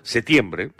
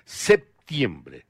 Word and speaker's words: septiembre: Igual, aquí en el Perú generalmente no pronunciamos mucho la P, septiembre, a secas, así, igual está bien pronunciado. En otros países se le da la acentuación en la P, septiembre, pero igual suena, ¿no?